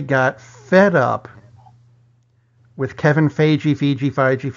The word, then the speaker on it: Feige: I was told definitively from Marvel it is Feige. – Feige. – Feige. As opposed to Feige, Feige, Foji, Foji.